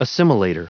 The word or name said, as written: assimilator